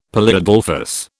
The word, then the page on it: polyadelphous: British English